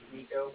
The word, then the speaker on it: Niko: My name's Nico, N-I-K-O, calling with the Bank of Cardiff. Cardiff is spelled C-A-R-D-I-F-F, and Frank.